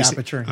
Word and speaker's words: Aputure: Do you say aperture? – I say aperture.